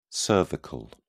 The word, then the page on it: cervical: British English